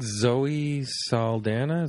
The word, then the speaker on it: Zoe Saldana: And I think Zoe Saldana, is that how you say it? – Yeah.